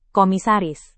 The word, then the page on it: komisaris: indonesian